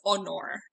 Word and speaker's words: honor: So like honor, H-O-N-O-R. For I had to actively coach myself into pronouncing it honor in Latin, because I always just wanted to read it as honor.